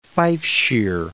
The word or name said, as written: Fifeshire